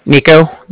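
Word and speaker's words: Niko: Nico. That's N-I-K-O. – Okay. – Calling with the Bank of Cardiff. Cardiff is spelled C-A-R-D-I-F-F, as in Frank.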